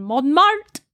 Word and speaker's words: Montmartre: He arrives in Montmartre.